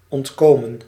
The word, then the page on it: échapper: France (Paris); /e.ʃa.pe/